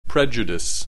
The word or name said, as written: prejudice